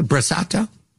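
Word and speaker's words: brasato: You do not overcook spaghetti, and you are very proud of your brassato. Did I say that correctly? Brassato? – Brassato and risotto.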